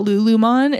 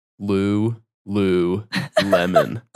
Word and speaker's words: Lululemon: It's L U L U L E M O N. Lou Lou Lemon.